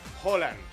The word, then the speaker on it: Haaland: Bueno, Alfredo González, comentarista de Fórmula Deportes, ¿cómo se pronuncia? – Holland. – Holland. – Holland. – Es con O. – Y es rápido, es Erling Haaland. – Haaland.